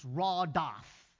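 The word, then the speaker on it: radaf (רדף): I don't know why they came up with the word follow, because the word in Hebrew, which I would probably struggle to pronounce, it's radaf.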